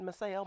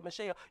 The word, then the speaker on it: Micshell: I'm like, my father, he said Michelle. My mama said Michelle.